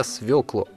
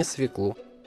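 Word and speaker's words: свёклу: При изменении его по падежам ударение не меняется. Так что в следующий раз отваривайте для винегрета свеклу, а не свеклу.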